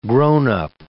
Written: grown-up

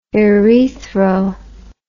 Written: urethro

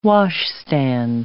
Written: washstand

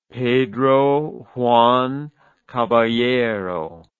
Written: Pedro Juan Caballero

